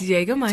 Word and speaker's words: Jägermeister: But I pronounce it out. – It's Jägermeister.